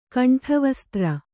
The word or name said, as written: ಖಂಡವಿಸ್ತಾರ